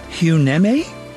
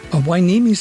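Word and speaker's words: Hueneme: Huneme? H-U-E-Wainimi? Wainimi. Oh, okay, my engineer told me it's Wainimi. He said he used to live there. Wainimi sounds like it should be on the Big Island.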